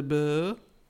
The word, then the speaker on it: Bourgh: Is that how I say that? B-O-U-R-G-H. De Bourgh. – Wait, spell it again. I'm dyslexic. – B-O-U-R-G-H. – Well, that's Bourgh.